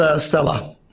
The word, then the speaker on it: Sela: Oh, it's Sela.